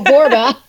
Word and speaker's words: Borba: I'm a 1L Michelle and Borba rhymes with Zorba. So it's Michelle Borba.